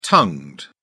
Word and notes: tongued: British English

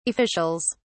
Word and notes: officials: British English

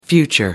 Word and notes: future: American English

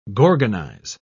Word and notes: gorgonize: British English

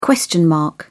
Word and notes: question mark: British English

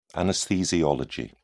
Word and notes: anesthesiology: British English

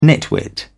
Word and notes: nitwit: British English

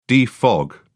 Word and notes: defog: British English

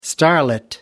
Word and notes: starlit: British English

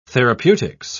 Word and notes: therapeutics: British English

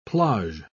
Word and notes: plage: British English

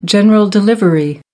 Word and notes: general delivery: American English

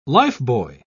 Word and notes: lifebuoy: British English

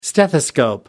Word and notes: stethoscope: American English

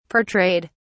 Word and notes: portrayed: British English

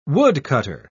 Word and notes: woodcutter: British English

